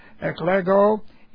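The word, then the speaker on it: Eklego (ἐκλέγω): Eklego, E-K-L-E-G, long O. Eklego.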